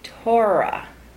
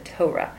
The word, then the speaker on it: Torah: So we absorb it from the, we don't pronounce it this way, Torah, right? We pronounce it Torah. But the center of the word is Or, really.